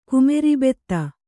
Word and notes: ಕುಮೆರಿಬೆತ್ತ: Kannada

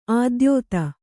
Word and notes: ಆದ್ಯತೆ: kannada; ādyate

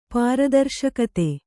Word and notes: ಪಾರದರ್ಶಕತೆ: pāra darśakate

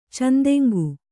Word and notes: ಚೆಂದೆಂಗು: cendeŋgu; Kannada

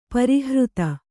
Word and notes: ಪರಿಹೃತಿ: parihřti

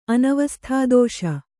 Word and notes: ಅನವಸ್ಥಾದೋಷ: Kannada; anavasthādōṣa

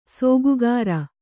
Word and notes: ಸೋಗುಗಾರ: sōgugāra

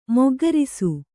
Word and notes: ಮೊಗ್ಗರಿಸು: moggarisu

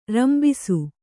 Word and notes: ರಂಬಿಸು: rambisu

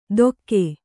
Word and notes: ದೊಕ್ಕೆ: dokke

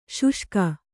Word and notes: ಶೋಷಕ: Kannada

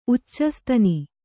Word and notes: ಉಚ್ಚಸ್ತನಿ: uccastani

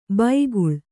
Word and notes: ಬೈಗುಳ: baiguḷa

